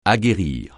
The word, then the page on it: aguerrir: français